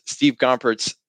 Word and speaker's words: Gompertz: And today with me is Steve Gompertz.